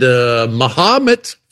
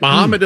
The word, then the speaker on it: Mahomet: I'm doing Vincennes and Columbus, Indiana, and Mahomet. Finally learned how to say it. Mahomet, Illinois.